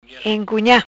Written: Encunha

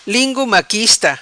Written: Lingu maquista

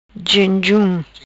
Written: Jenjum

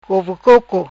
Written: Cove Coco

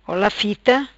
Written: Fita Ola

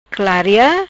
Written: Claria

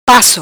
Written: paco

